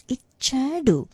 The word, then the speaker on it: ichadu: And then the last word is ichadu. The ch sound there is a double ch, so bear in mind you have to stress a little bit on the ch, and there's a slightest roll of the tongue.